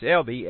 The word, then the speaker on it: Elba: And we'll find as we come to this chapter 2 on Elba, it's pronounced Elby, as in Ida's name is pronounced Idy. Now, my grandma's name was Meda, M-E-D-A, but it was pronounced Meedy.